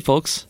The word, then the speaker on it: folks: Do you say the L in folks? – Mm. Hey, folks.